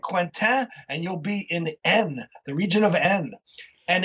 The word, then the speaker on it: Aisne: And the place we're going to talk about today is A I S N E. It's pronounced N, and it's in northern France.